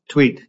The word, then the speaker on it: Thweatt: You say that the video of the incident failed to show, you said your client's name is pronounced Thweatt, is that correct? – Thweatt, yes.